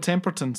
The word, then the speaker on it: Temperton: This song was co-written with Rod Temperman. – You're fucking manning. – Rod Temperton, sorry. – Temperman. – Temperton. I don't think we're ever going to pronounce this guy's name right.